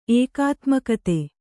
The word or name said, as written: ಏಕಾತ್ಮಕತೆ